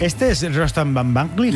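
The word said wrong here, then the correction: Batmanglij: Casi bien pronunciado, ¿eh? O sea, tercero de Radio 3 tiene casi. – Van Banglig. – Batmanglig. – Batmanglig.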